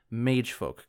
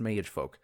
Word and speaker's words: Maegfolc: So I'm pretty sure that's pronounced Maegfolc.